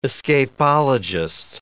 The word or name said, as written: escapologist